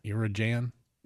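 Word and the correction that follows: Inarajan: Irajan? – In Arahan? – Yeah, there you go.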